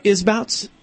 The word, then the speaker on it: Isbouts: Am I saying that right, Isbouts? – You're saying it correct.